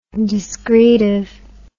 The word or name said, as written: discretive